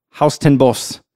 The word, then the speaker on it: Huis ten Bosch: So the place is spelled H-U-I-S space T-E-N space B-O-S-C-H. So when I first saw it, I'm like, Huis ten Bosch? I don't know how it's pronounced in Dutch, but right. – I watched a video, Huis ten Bosch is how it's pronounced in Japanese.